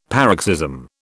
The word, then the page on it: paroxysm: British English